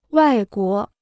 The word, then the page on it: 外国: wài guó